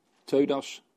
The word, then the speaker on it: Theudas: Dat waren Judas en Theudas, geloof ik. Zeg ik dat goed? Theudas en Judas, ja, de Galilea.